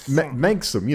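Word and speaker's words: manxome: Oh yeah, the natural combination of NXS that we all know how to pronounce. – Yeah, manxum, you know, manxum. From the Isle of Man.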